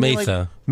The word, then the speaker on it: Matha: So do you think that, so they clearly buried Dan Martha, right? So, Matha. – Matha. – I put a Y in it so I even know how to pronounce it.